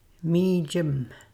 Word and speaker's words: mijim: Mijim. Mijim. – Listen to the M at the end of that word.